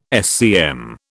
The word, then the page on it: SCM: British English